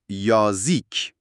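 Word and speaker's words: یازیک (язык): کلمه که در موردش صحبت میکنیم یزیک هست. تلفظ دقیقش یازیک هست. با تکیه روی هجای دوم.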